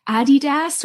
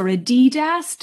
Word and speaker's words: Adidas: And now we go across to Portland to say hello to Aaron Sebron, VP US Sports at Adidas or Adidas, depending on your accent on the day.